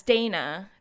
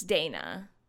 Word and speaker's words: Dana: Also, I didn't know you were foreign, because the last people and, you know, that call me Donna is when I'm in Spain or, you know, someone from a different culture, right? Because they pronounce their words and syllables like differently. So I'm like, Jesus Christ, like, I'm like, oh yeah, it's Dana. It's Dana, you know.